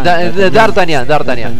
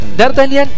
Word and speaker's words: D'Artagnan: D'Artagnan. Ahí está. D'Artagnan no hay idol Sengen. D'Artagnan, para los sí, que no sepan francés, D'Artagnan se escribe de una manera muy diferente a cómo se pronuncia.